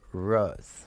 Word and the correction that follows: Rouse: Am I saying that correctly, Mr. Rose? – It's Rouse, like Mouse, except with an R. – Oh, Rouse like Mickey Mouse.